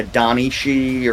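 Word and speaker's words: Daoine Sìth: I said Sith, but it should be she. It's like the Donnie she or something like that.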